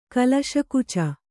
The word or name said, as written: ಕಲಶಕುಚ